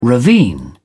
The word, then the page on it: ravine: British English